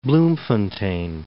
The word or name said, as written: Bloemfontein